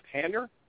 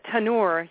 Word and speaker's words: tannur: But what is a How do you say it? Tanner? T-A-N-N-U-R. – Oh, a tanur, yeah.